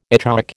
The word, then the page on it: echoic: American English